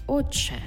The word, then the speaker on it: отче: Тобто пишемо «отче», а вимовляємо «отче».